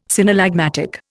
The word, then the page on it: synallagmatic: Indian